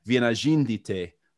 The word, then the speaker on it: Vienažindytė: Today I'm delighted to be joined by lawyer and lecturer, Dr. Jevgenija Vienažindytė.